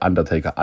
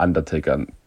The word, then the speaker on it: Undertaker: also ich habe das immer deutsch ausgesprochen, was da, äh, was da drauf stand. Und es war teilweise sehr peinlich, dass ich erst sehr spät gemerkt habe, dass der Undertaker Undertaker äh, heißt.